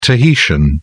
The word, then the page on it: tahitian: British English